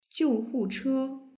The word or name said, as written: jiuhuche